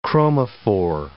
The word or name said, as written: chromophore